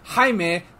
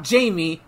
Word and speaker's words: Jaime: I will pronounce it Jaime, not Jamie. It's Jaime when I see that name.